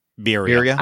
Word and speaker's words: birria: Is it Berea? Berea Tacos? – Birria. – I don't know. Birria. Okay. See, there you go.